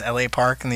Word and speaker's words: La Parka: One pronounces his name as L.A. Park and the other one La Parka.